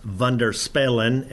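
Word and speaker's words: Wonderspelen: So Wonderspellen is more or less, from a Dutch standpoint, is pronounced Wonderspellen, and we have been corrected over the time doing presentations and such, pronouncing it the correct way.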